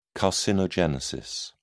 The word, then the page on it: carcinogenesis: British English